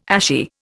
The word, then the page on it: ashy: Indian